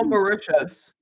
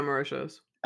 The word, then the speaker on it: Mauritius: Filming took place in London and I can't pronounce it. It's like Maritiana. – Mauritius. – It's in North Mauritius. America. Yeah. – Yeah, Mauritius.